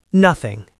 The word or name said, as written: nothing